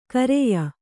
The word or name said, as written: ಖರೆಯ